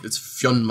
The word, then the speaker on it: Fionn: The easiest and most anglicized version of this is Finn McCool. – Finn McCool, yes. – But it's actually Fionn McCool.